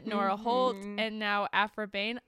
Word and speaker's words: Behn: Because Behn is spelled B-E-H-N. I think that's Behn.